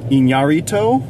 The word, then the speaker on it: Iñárritu: This is written and directed by Alejandro González Iñárritu.